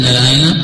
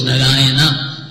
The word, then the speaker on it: Narayana: And when he said Narayana, Prabhupada said four syllables, Narayana, he said.